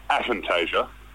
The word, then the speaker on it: aphantasia: what I ended up finding was that he actually said it wrong, and it's aphantasia. – Okay. – Capital A, PH. So not fantasia like that weird Mickey Mouse musical documentary we all watch as kids.